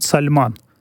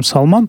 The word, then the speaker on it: Сальман: Он, кстати, правильно его называет Сальман по-арабски. Это мы ее называем Салман, потому что привыкли уже, скажем так, опыт общения с нашими близлежащими, да, мусульманскими, в частности, тюркскими народами, где это имя произносится как Салман, в русском языке именно так и принято называть. Его зовут Сальман, и Мухаммад бен Сальман, он наследный принц, формирует сейчас действительно ту самую политику, которая у меня всегда вызывает эффект такого дежавю.